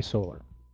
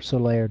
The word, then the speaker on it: Soler: Earlier this week, or around this weekend, you might be familiar with the name Jorge Soler. Or Soler, depending on how you want to pronounce it. I've heard it both ways.